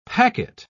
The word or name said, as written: packet